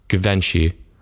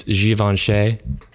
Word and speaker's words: Givenchy: I was usually saying Givenchy. Um, but apparently it's Givenchy.